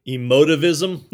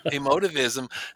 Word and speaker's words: emotivism: Uh, you were talking about explaining uh, emotive emotism. How did you say it? – Emotivism. Emotivism.